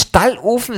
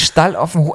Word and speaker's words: Stalloffen: Es ist nicht Stallofen, es ist Stalloffen, Also von wegen Hosenstalloffen.